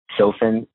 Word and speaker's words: Sofen: So responsible, or you can find me anywhere, kind of those same platforms in my personal pages, Kevin Sofen, S-O-F-E-N.